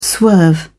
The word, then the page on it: swerve: British English